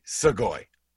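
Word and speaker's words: Sugoi (すごい): I'll just say Sugoi. – Sugoi. – And that means – Sugoi means great. – Sugoi.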